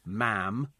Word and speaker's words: ma'am: So thank you, ma'am, to rhyme with jam. OK, not mom to rhyme with smarm. So it's jam and ma'am.